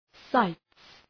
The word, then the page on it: sights: saıts